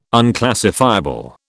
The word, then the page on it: unclassifiable: British English